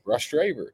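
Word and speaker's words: Rostraver: Why do you say it Ross Draver, like Latrobe? – That's how you say it, Ross Draver.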